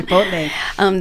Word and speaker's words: Chipotle: And most people have heard already about the Popeyes that's going in, and the Chipotle. – Yes. – Is that how you say it? – Yes. – Okay. – Chipotle.